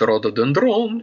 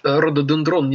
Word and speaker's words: рододендрон: Помните песню «Этот рододендрон, и где торгует южным вином?» Так вот, рододендрон неправильно. Макаревич этого не знал, но ударение надо ставить рододендрон.